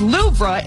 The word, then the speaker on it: Louvre: The weight loss drug is high on the list, along with Storm Aowen and the Louvre Museum, which is pronounced Louvre in France.